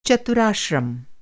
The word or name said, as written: चतुराश्रम